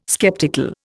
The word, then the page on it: skeptical: Indian